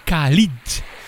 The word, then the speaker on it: Khalid: Hoor je Ed Sheeran featuring Khalid. Zo spreek je dat uit, met hun Beautiful People.